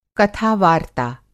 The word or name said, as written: कथा-वार्ता